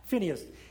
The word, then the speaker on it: Phinehas: On Hophni and Phinehas. That's how you pronounce? Phinehas.